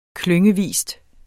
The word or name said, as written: klyngevis